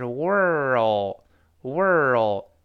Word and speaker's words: world: 这个 w 是双唇碰撞发 w w w，后面那个 or 发 er er，后面还 l 发 o o，就是哦，明白了那个哦，所以放在一块是 world world，再加后面那个的，我们读一下 world world，哎。